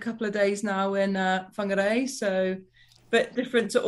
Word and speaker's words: Whangarei: Whangarei. – Whangarei.